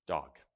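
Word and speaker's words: dog (דג): the Hebrew word for fish is pronounced dog. That's how you would say it, dog, but it means fish.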